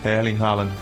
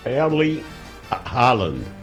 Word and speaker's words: Erling Haaland: Erling Haaland. – É que viralizou aí uma pronúncia meio aportuguesada do nome dele, que não é muito bom a gente falar, não.